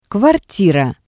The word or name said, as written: kvartira